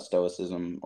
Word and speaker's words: Stoicism: I don't know, but I heard this guy on a podcast, actually, he was talking about Stoicism I'm not sure if I'm pronouncing that right. – You are.